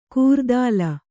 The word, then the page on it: ಕೂರ್ದಾಲ: kūrdāla